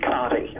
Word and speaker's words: Conte: And you appeared in The Sleeping City with Richard, is it pronounced Conte? – Yes, Richard Conte.